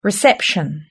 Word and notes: reception: British English

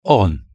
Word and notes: on: British English

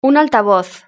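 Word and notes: un altavoz: Spanish (Spain)

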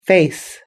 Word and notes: face: British English

